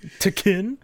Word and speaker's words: Tekken: Who could not pronounce Tekken? It's Tekken? – Tekken? Yeah.